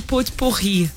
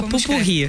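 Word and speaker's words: pot-pourri: É porque se escreve putipurri. – É, e mas você fala pupurri.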